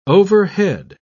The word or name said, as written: overhead